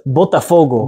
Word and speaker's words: Botafogo: Sava on his way to Brazil, and I'm going to butcher the name. – It's Botafogo.